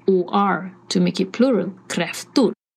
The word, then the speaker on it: kräftor: And kräfta, one crayfish, flera eller många kräftor. Many crayfish. Kräfta. Kräftor. Kräfta. Singular. Kräftor. Plural. So it's spelled K-R-A with the 2 dots on the top.